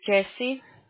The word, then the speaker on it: Jessie: My name is Jesse. – Can you spell it, please? – J-E. – Is it J-E-S-S-I-E? – Yes. – And then last name? – T-O-R-R-E-S.